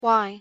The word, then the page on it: why: English